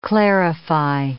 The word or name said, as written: clarify